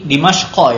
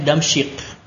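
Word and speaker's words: Dimashqo (دمشق): Ini Dimashqo ya bacanya ya. Sebagian yakni saya perhatikan tolip-tolip di Indonesia ini bacanya Damsyik. Ah itu tidak tepat ya. Lebih cepat itu Dimashqo. Bacanya Dimashqo.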